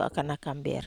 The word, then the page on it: akanak amber: Wamesa